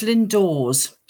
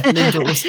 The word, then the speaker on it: Lindores: So it's Lindores. So it sort of rhymes with oars. in a boat, really. So yeah, Lindores.